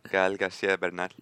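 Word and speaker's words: Gael García Bernal: Gael Garcia Bernal.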